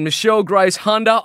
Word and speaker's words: Michelle Grace Hunder: Claudia Sangiorgi Dalimore and Michelle Grace Hunder. I hope I've pronounced those names correctly. – That's pretty close. – Pretty close? – Yes, well done.